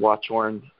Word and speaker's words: Watchorn: Ian Watchorn, again, my surname is spelled W-A-T-C-H-O-R-N. Ian is I-A-N.